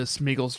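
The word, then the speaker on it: Sméagol: I think the accent is on the E, and I would say it's Smeagol. Yeah.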